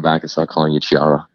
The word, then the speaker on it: Ciara: I do change it, not to burst their bubble, but I tried to fight because the way this is written, the pronunciation is Ciara. However, I was beaten over the head by Christian Alfonso and a few other people that it is Ciara, which would be S-I-E-R-R-A. But I tried to fight it. I tried to fight it. – Technically, the way Ciara's name is spelled, it should be Ciara with a C-H because it's Italian.